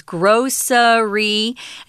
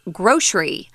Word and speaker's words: grocery: And not just a difference with that S sound um, you can, there are two pronunciations you'll find in dictionaries. One is grocery, and the other one is two syllables, grocery.